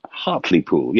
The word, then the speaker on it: Hartlepool: Hartlepool, Hartlepool, yes, up in the northeast of England, in Yorkshire sort of territory.